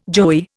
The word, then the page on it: joey: Indian